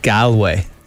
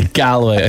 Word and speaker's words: Galway: It's like G-A-W-L. That's the way you pronounce it. – No, G-O-L. Galway.